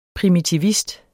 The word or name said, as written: primitivist